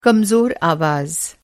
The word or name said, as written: कमज़ोर